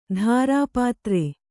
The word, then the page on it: ಧಾರಾಪಾತ್ರೆ: dhārā pāte